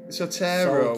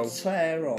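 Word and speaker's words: Soetoro: Barry Soltaro. – Is that Soltaro? – How's he? – Soltana, I think. Is it Soltana? Where is it? – Sotero. – Sotero. – Barry Sotero. Yeah.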